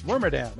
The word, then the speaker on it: Wormadam: How do you pronounce this? Wormadam? – Irmadam. – Wormadam. Okay, I did say it right.